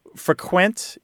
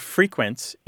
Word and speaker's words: frequent: So just to be clear here, because I might have misspoke a little earlier, frequent is the verb and frequent is the adjective, and they have different stress. Frequent has the stress on the second syllable. Frequent has the stress on the first syllable.